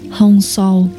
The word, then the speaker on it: Hong Sau: Ahansa hay Hansa đọc là Hong Sau.